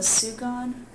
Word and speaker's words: sugan: Have you ever heard of a sugan or a sugan? It's spelled S-U-G-A-N. And the only person I've ever heard pronounce it, they pronounced it sugan, and they thought it was like a bedroll that the Indians would use.